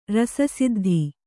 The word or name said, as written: ರಸಸಿದ್ಧಿ